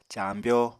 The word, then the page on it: caambyó: apatani